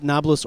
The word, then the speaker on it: Nablus: But the enemies of Israel often you call it Nablus or Nablus. With a B, because they don't pronounce the P very well.